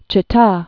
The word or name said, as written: Chita